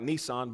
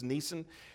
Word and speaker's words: Nisan: It would almost sound like Nissan, but it's Nisan.